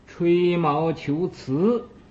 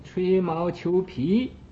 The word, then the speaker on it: 吹毛求疵: 慈啊，有那么一句好话，说是啊吹毛求疵。有的不认字的人呢，就念成吹毛求皮。